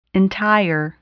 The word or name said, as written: entire